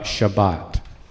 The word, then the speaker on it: Shabbat: And we'll spend some time talking about Shabbat. Shabbat is the Hebrew pronunciation of Sabbath.